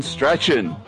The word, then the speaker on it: Strachan: Shirley Strawn. Rest in peace, Shirley Strawn. Strachan, if you actually want to pronounce it correctly.